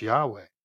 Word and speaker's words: Yahweh: It's rendered in Hebrew, like I said, which has no vowels in its alphabet, as YHWH, usually pronounced Yahweh.